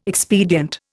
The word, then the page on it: expedient: Indian